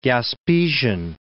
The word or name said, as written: Gaspesian